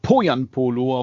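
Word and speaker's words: Pohjanpalo: Also Poyampolo auf der ersten Silbe.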